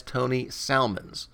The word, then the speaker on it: Salmons: He also gave his opinion about how to pronounce the name of the artist on that issue, which I said as Tony Salmons. Martin disagreed. P.S. No way is that L pronounced. It's too fishy.